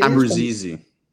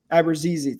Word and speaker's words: Abruzzese: Nick Abruzzese, I think it Abruzzese. – Abruzzese, thank you. From Toronto. – There's a couple different ways to pronounce, but that's the most common way I've heard.